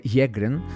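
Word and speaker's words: Sjögren: Το σύνδρομο Σέγκρεν πήρε το όνομά του από τον Σουηδό οφθαλμίατρο Χένρικ Σέγκρεν. Στα σουηδικά προφέρεται Γέγκρεν, ο οποίος το περιέγραψε για πρώτη φορά το 1933 το σύνδρομο αυτό.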